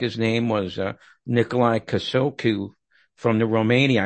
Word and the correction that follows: Ceaușescu: I probably pronounced that wrong. You can do a better job on that. – I think it's pronounced Ceaușescu.